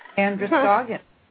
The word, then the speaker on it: Androscoggin: Androscoggin.